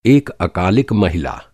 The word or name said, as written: अकालिक